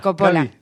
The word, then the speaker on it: Coppola: Coppola.